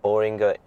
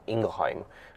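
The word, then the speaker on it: Boehringer Ingelheim: Uh, properly either, but um, the way that you pronounce BI is Boehringer Ingelheim.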